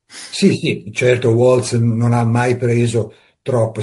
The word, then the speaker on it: Waltz: Kenneth Waltz, come lei dice, pronuncia, io lo faccio alla tedesca perché è di origine tedesca il nome, secondo me, quindi, l'autore Waltz